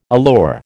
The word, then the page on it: allure: American English